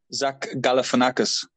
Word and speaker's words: Galifianakis: Zach Galifianakis.